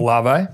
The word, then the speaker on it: Olave: Olave.